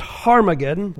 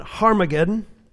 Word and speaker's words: Harmageddon: The word Armageddon is pronounced Harmageddon, with a hard H in front of it, Harmageddon, and means Mount Megiddo.